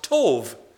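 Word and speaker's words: tov (טוב): It's pronounced tov. T-O-V.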